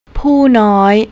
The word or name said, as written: punoi